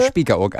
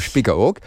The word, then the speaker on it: Spiekeroog: Muss man das mit einem Spitzen aussprechen? Nee, Spiekeroog eigentlich. – Ich mach's nicht. – Guck mal, Spiekeroog.